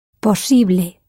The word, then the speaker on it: posible: One of Michelle Thomas's very first examples explained how English words ending in I-B-L-E and A-B-L-E are the same words in Spanish, except for their pronunciación. Using this handy tool, the English possible becomes posible, pronounced ible in Spanish. So how do you say possible in Spanish? – Posible.